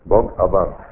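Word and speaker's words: Бхагаван (भगवान): Бхагаван. Это санскритское слово. Бхагаван состоит из двух частей. Бхаг – это Бог. Ха в санскрите не читается, помните, я вам рассказывал.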